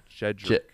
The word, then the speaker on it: Jedrick: Jedrick.